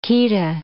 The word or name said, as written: chaeta